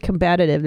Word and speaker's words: combative: That he's like, it's very common for people who smoke meth to approach and become combative with multiple police officers. – I think you mean combative. There's an extra syllable.